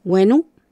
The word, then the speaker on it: Wenu: Wenu, ¿cierto? Y se deletrea W-E-N-U, Wenu.